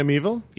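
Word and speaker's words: Miéville: How do you pronounce his last name? – China Meevil? – Yeah.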